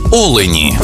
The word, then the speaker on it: олені: І в однині, і в множині наголос падає на перший склад: олень та олені.